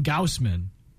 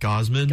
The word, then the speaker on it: Gausman: At number two, north of the border, please correct me if I say this man's last name wrong, Kevin Gausman. – I say more like Gosman. – Gosman? – But Gosman? I haven't necessarily heard it from like an MLB analyst. – It seems like German spelling, so that's why I said Gaussman.